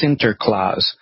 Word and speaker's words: Sinterklaas: Because they call it Sinterklaas.